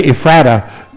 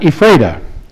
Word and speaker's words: Ephrata: And it's a Bible name, by the way, Ephrata, but that's what they say in Pennsylvania. There's only one other church town in the country, but we pronounced it Ephrata in Washington State.